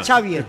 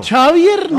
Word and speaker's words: Xavier: Xavier, no creo. – No, Xavier. Yo sí creo porque ese es Vasco. La X se pronuncia Che.